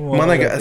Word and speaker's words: Monogatari: Is that how you pronounce it? – Monogatari? – Monogatari. It's close enough.